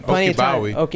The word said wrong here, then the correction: Ogunbowale: Oh, Ogunbowale. – There we go. – Oh, that's how you say it.